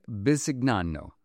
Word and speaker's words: Bisignano: And reader's note, the spelling of that last name is B-I-S-I-G-N-A-N-O, and for the purposes of this story, we're going to pronounce it Bisignano.